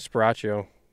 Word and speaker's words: Sparacio: Christian Sparacio.